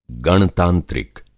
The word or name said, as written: गणतंत्रिक